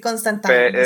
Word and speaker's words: Constantine: Pero bueno, muchas gracias, mi Oye, eh, ¿y ese era Constantine o Constantine o cómo se pronunciaba? – Constantine era, creo que la manera correcta, ¿no? Sí, porque. – Sí. Sí, Constantine es eh, la manera correcta.